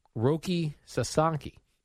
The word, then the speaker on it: Roki Sasaki: During one of these games, Jerry, Japanese pitcher Roki Sasaki.